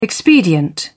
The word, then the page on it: expedient: British English